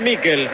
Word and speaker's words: Mikel: Se escribe Mikel. ¿Cómo le digo? – Decile Mikel.